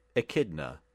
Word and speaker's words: Echidna: Echidna.